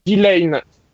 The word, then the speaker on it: Delain: Pardon, le deuxième c'est Delay ou Delane Delane, ça s'écrit D-E-L-A-I-N.